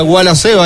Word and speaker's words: Gualaceo: Segundo tiro de esquina del partido, el segundo para Gualaceo, ya lo hará efectivo y se pronuncia Gualaceo ahí en el ataque, ¿no? – Sí, señor, hay que trabajar desde atrás.